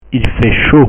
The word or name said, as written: il fait chaud